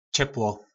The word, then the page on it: Čeplo: Po našimu